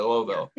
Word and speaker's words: Louisville: You got to say it like you're, there's a saying, say Louisville like you're drinking bourbon. You know, Just slurred a little bit, Louisville.